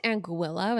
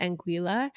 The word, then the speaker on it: Anguilla: It's actually pronounced Anguilla, but that sounds incorrect. So I'm going to keep calling it Anguilla.